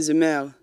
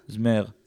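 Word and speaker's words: Zemmour: אריק זומר? – זומר? איך הוא נקרא? איך מבטאים את זה? אמ, את טובה בהגיות צרפתיות. – נראה לי שזה זמר. – זמר.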